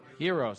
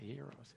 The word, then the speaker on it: Gyros: Gyros. How do you say that, by the way? Heroes. See that? Heroes.